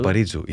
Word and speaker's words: Paparizou: og det er jo Elena Paparizou. Hvordan uttaler du det? – Paparizou, ja, den greske vinneren.